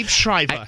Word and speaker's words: Liev Schreiber: Liev Schreiber. – Liev Schreiber? – Yeah, I love him. – I can't pronounce his last name. – Yeah, Liev Schreiber.